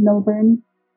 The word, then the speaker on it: Millburn: It's under my name, Sarah without an H, S-A-R-A, and then Millburn with two L's.